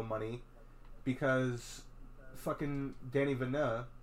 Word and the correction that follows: Denis Villeneuve: or however the fuck you pronounce his name, wanted Denis Villeneuve. – Yes, Denis Villeneuve.